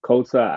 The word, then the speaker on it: KOTA: And yo, KOTA, am I pronouncing that right? – Yes.